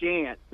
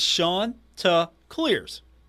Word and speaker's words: Chanticleers: Oh, when you say shant, the Chanticleers, that drives people crazy because it ain't the Chanticleers. You got to say the shant. You know, that's how it's pronounced. And people do that on purpose. They just say the Chanticleers. And it's like, oh, no, that ain't how you say it. That's not it. Come on, the Chanticleers.